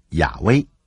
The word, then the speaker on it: 耶和华: 这四个字母是 JHVH，也就是上帝的名字，我们念作耶和华，希伯来文念作亚威。为了帮助你们了解，这里用英文的 Lord L-O-R-D 来取代希伯来文的 J-H-V-H，也就是耶和华或是亚威。